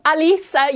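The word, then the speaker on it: Halys: You've completely shamed me there with your pronunciation. We're actually talking about the same person, Quentin Halas and Quentin Alice. You've absolutely nailed it much better than me.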